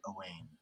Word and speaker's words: Owain: How do you spell it? – Owain. It does look like, I think it really is said Owain.